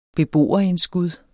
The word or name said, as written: beboerindskud